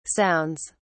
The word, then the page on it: sounds: British English